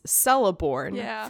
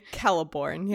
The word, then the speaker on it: Celeborn: So the pronunciations of stuff, so the consonants, C always has the value of K. And I remember this was one of the first ones that I was corrected about, because I was pronouncing Galadriel's husband, Celeborn, as Celeborn for a while. That was actually Celeborn.